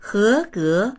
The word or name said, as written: he2ge2